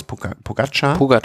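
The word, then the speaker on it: Pogačar: da dahinter Pogacar, wie spricht man aus? – Pogacar.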